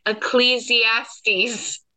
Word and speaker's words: Ecclesiastes: So friends, I'm gonna read it, but don't laugh at me, because the reason I'm having Jen read it is because I cannot pronounce Ecclesiastes. – You did it fine.